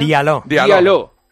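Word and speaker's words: Djaló: Diallo.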